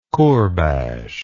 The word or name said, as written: kourbash